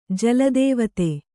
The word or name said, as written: ಜಲದೇವತೆ